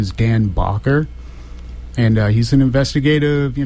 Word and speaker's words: Bacher: Go to the internet and just Google search Dan Bakker, and his last name is spelled B-A-C-H-E-R, Dan Bakker,